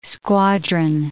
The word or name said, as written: squadron